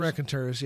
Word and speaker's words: Raconteurs: How do you say this name? The Raconteurs? – Raconteurs, yeah.